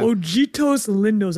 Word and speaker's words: Ojitos Lindos: Ojitos Lindos.